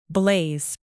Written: blaze

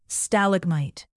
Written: stalagmite

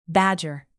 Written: badger

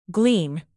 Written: glean

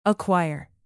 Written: aquire

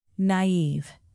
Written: naive

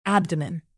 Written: abdomen

